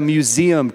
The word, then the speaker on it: museum: In fact, that's the only feedback I've gotten on this whole series, is that I don't say museum right, and now I know that's a three-syllable word, not a two-syllable word. But as a museum curator, you might be able to say, hey, what's this painting about?